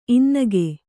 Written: ಇನ್ನಕ

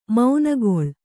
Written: ಮೊನೆಗೊಳ್ಳು

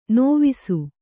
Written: ನೇವಸ

